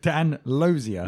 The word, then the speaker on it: Glozier: Dan Glozier asks... – Did we ever establish if it's Glozier or Glozier? – I think it's a silent G. – Dan Lozier.